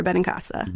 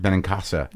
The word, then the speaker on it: Benincasa: Yes, Sara Benincasa. – Benincasa.